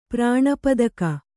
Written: ಪ್ರಾಣಪದಕ

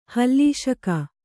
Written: ಹಲಸಿಗ

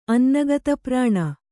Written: ಅನ್ನಗತಪ್ರಾಣ

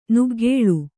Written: ನಗ್ಗಲು

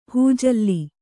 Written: ಹಿಜ್ಜಲ

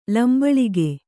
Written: ಲಂಬಳಿಗೆ

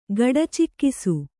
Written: ಗಡಚಿಕ್ಕಿಸು

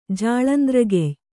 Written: ಜಾಳಂದ್ರಗೆಯ್

